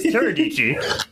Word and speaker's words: Teradici: We've been trying to work a little bit with, it's Teradici, right? Because I kept confusing it with you. – Reminds of rhymes with Dici. – Yeah, so it's Teradici.